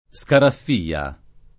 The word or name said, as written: Scaraffia